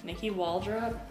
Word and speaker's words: Nikki Waltrip: Well, how do you actually say your name? – Nikki Waltrip. – Oh, just like I did? – Yeah, I guess.